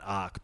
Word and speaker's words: RetroArch: I think some people call it Arc, but it's spelled Arch and I'm not going to fucking change in my ways.